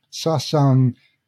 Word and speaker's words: Sasang: Sasang is the Korean way of pronouncing the characters that in Chinese we would call Suxiang, the 4 images from the I Ching.